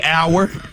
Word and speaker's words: hour: This was another one that threw me hour. – Hour. – Yeah, like time. – Yeah. – The H is silent. – Exactly. H-O-U-R, the H is silent.